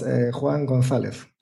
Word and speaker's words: González: Yes, Juan González.